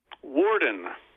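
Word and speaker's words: Worden: He's pastor at Zion Lutheran Church in Carpenter, Illinois, and also another congregation in Illinois, Trinity Lutheran Church in, do you pronounce this Warden? – Warden. – It's Warden.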